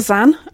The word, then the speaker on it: Xan: Peter Zan. That is spelt X-A-N. – Mm-hmm. – I realise I ought to clarify that, because it's a tricky one from just pronunciation.